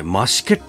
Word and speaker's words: 増毛: 北海道にということをね、ツイートすると、はい、やたらとですね、増し毛っていうところには行ったんですかと。 – 増毛と書いて増し毛と読むんですけど